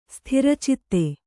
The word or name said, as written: ಸ್ಥಿರಚಿತ್ತೆ